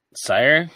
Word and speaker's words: Cyr: I don't know how to say it. C-Y-R. – Sire? – Sure.